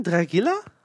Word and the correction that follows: Drigalla: Drigala heißt Drigalla.